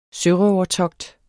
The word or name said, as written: sørøvertogt